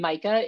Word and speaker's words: Majka: And, you know, my name, Majka, is spelled M-A-J-K-A. It's Polish.